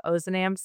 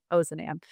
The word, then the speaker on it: Ozanam: And I will just say, before we get too far into this, we have a very Midwestern pronunciation of Ozanam, so you'll hear me saying that a lot. Probably other folks from the society across the country and across the world would pronounce that differently, but we've really gotten in our habit here of saying Ozanam.